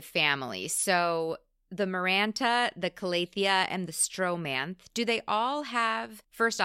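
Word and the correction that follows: Stromanthe: My understanding is that the last one is Stromanthi. – Stromanthi, okay.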